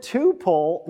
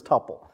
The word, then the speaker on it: tuple: Total ordinary rules for C++, so from now on, if you catch anybody saying tuple, yeah, point them to YouTube, marked at this point in time, and it's pronounced tuple. Tuple, okay.